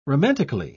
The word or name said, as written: romantically